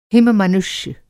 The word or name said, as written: हिम-मनुष्य